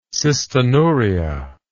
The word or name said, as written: cystinuria